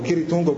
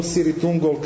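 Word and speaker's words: Cirith Ungol: Drugi bend iz tog talasa, Marko je pomenuo, bend koji dolazi sa zapadne obale, Siri Tungol ili Kiri Tungol. Kiri Tungol po Tolkienu, Siri Tungol kako sami članovi benda kažu da bi trebalo ime da se izgovara.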